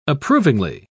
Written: approvingly

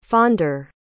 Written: fonder

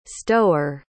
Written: stower

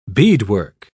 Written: beadwork

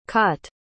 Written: cott

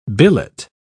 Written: billet

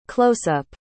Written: closeup

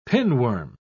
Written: pinworm

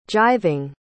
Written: jiving

